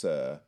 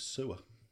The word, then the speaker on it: Soeur: Band from Bristol called Sir, and it's spelt S-O-E-U-R. It's French for sister. I'm pretty sure it's pronounced Sir. – Yeah, I thought it was like a weird spelling of sewer. – Yeah, I'm pretty sure no one wants to be called Sewer.